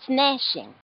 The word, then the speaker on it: gnashing: N-A-S? – It starts with a G. It's gnashing. G-N-A-S-H-I-N-G. – I get it. Gnashing. – No. The G is silent.